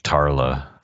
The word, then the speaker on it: Tala: Because Tala, how do you say her? – I think it was Tala. – Tala. – Yeah. – Tala.